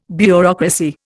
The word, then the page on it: bureaucracy: Indian